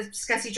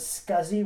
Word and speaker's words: SCSI: I've always thought it was SCSI with a Z sound. And oh, yeah. it sounds cooler, but I guess, you know, after all these years, I, I was pronouncing it wrong the whole time.